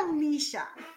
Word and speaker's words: Ellicia: And I pronounce it Ellicia.